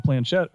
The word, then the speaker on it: planchette: they're holding the planchette or however you pronounce it. – Yep, planchette.